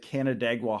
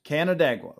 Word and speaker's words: Canandaigua: Did you dig into Ryan Poles at Canandaigua High School, which is actually, that's how you say it. I've been like, when I see it, I'm like, Canada, like that's how I pronounce it to myself, because I don't know how to, can you say it again?